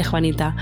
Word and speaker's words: Juanita: muy interesante de Juanita.